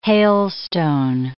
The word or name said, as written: hailstone